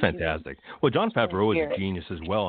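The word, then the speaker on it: Jon Favreau: I think the one person who might have known was John Favreau. You know, am I saying his name correctly? The one that directed... – John Favreau, yeah.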